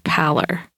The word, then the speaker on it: pallor: I'm pretty sure that the word is pronounced pallor.